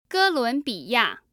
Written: gelunbiya

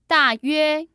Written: dayue

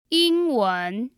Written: yingwen

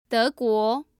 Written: deguo

